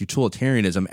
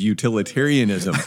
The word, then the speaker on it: utilitarianism: This is why I think Christians should utterly, completely reject utilitarianism as an ethical system. – If you're wondering if is mispronouncing that word consistently, yes, you are correct. It is utilitarianism.